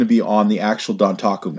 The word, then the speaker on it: Dontaku: That's how you say it. Dontaku. I've been mispronouncing it all weekend.